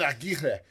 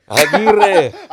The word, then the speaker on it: Aguirre: It's like, come on, Aguirre. – Aguirre. – Aguirre. – You got to roll him.